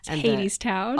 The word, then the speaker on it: Hadestown: And then the text on the bottom, it's either Hadestown or Hadestown. – Hadestown. – Oh, perfect. Okay. Great. Pronounced it right the second time.